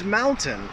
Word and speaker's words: mountain: And the word is mountain. I hear this one, my students always pronounce it mountain, mountain. So that last, that suffix there, that tain, needs to be more of a tin.